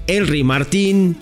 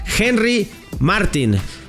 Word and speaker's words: Martín: Entonces es Henry, la H en este caso no es muda, es Henry Martin. No, Henry Martín, ¿no? No es Titi Henry, no es Henry Martín, no. Henry Martin, para que nos lo vayamos aprendiendo.